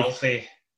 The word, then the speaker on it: Aoife: Is that how you pronounce her name? Aoife, spelled A-O-I-F-E, I think. Aoife Valkyrie. – Alfie. Her name's Alfie. Alfie.